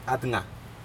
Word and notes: atga: Aneityum

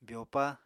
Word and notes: byopa: apatani

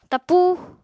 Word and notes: tapúu: Apatani

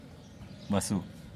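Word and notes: basu: Teotitlán del Valle Zapotec

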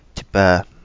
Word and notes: Tipae: Kapingamarangi

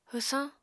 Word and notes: hwsañ: apatani

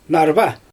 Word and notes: narva: Xyzyl

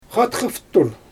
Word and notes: xatxafrtur: Xyzyl

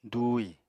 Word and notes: dùuhi: apatani

